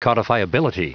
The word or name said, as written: codifiability